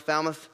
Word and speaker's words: Falmouth: Falmouth, you got to let that kind of fall out of your mouth, Falmouth.